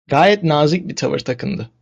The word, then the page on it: nazik: turkish